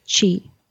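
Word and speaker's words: qi: Q-I. That's chi.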